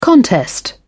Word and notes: contest: British English

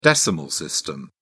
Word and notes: decimal system: British English

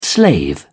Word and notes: slave: British English

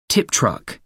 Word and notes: tip truck: American English

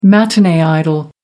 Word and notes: matinee idol: American English

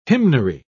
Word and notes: hymnary: British English

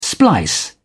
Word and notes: splice: British English

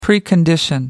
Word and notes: precondition: American English

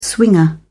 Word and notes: swinger: British English

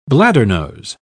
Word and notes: bladdernose: British English

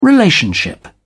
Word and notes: relationship: British English

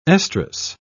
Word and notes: estrus: British English

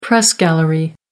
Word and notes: press gallery: American English

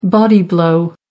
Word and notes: body blow: American English

